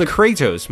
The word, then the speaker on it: Kratos: They say it in game, and it's Kratos, my dude.